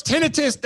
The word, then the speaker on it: tinnitus: And somebody's like, tinnitus. I'm like, no, it's tinnitus. That's pronounced. You're saying it the wrong way.